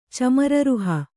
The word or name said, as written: ಚಮರೀರುಹ